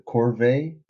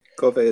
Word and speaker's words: corvée: But in the case of like peasantry in, I don't know how to pronounce it, the corvée? – Corvée is right. – I think it is corvée, yeah.